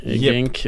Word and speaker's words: Genk: Men Omar Colley, mittback, eh, som har varit på gång till eh, Genk, Gink. – Genk yep. Eh, uttalet eh, reserverar jag mig för.